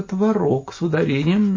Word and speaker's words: творог: Заметьте, у нас в Москве творог не едят, а только творог с ударением на втором слоге.